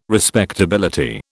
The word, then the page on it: respectability: British English